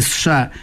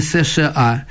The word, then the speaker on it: США: То її треба й вимовляти США, а не США, ВАК, а не ВАК. Тобто називати звуки, а не літери.